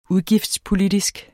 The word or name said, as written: udgiftspolitisk